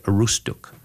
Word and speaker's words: Aroostook: And if you go further up the Wolustug, there's a branch that goes into the U.S., and it's still called Wolustug, but they don't pronounce it Wolustug. They call it Aroostook.